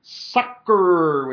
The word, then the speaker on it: succor: The King James translated it succor, which I remember as a kid going, I don't know what that means. Is that... You know, I don't know what it's not spelled like sucker, but it's a weird word.